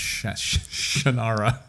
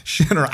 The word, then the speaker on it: Shannara: He goes, "It's not pronounced that way. It's pronounced Shannara, Shannara."